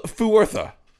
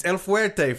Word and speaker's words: El Fuerte: It is El Fuertha.